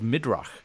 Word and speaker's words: midrash: midrash. Have I said that right? Midrash? – Yeah, midrash.